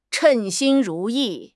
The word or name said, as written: 称心如意